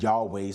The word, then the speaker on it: Yahweh: We pronounce it Yahweh, some call it Yahweh, but and that, but again, we the pronunciation of God's name has been lost over time.